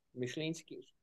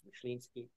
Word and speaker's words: Myślinski: Myślinski, my last name, to say it right, is Polish. It's Myślinski, and so I like to do the polka.